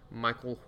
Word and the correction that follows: Mikel: Horton, M I K E L, Michael, Mikel. I'm sorry.